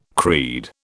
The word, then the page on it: creed: British English